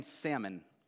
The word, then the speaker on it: Salmon: I had a teacher in eighth grade who called him the fish Salmon, right? She wanted to pronounce it, I guess. We pronounce it with a silent L, except for the name Salmon, all right?